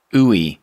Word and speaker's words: Uwe: We're talking to Edric Uwe. Did I say it right? – Yep, you got it. – I got it. Edric Uwe, who's a math teacher at Calavera Hills.